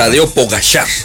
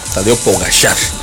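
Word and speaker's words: Tadej Pogačar: Tadeo Pogacar, así se pronuncia. Tadeo Pogacar.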